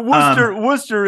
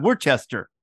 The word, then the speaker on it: Worcester: But Worcester is a tough one because it looks like Worcester. – Yes. – But it's Worcester. Yes.